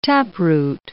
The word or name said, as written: taproot